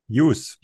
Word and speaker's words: Hughes: Hughes.